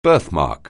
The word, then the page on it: birthmark: anglais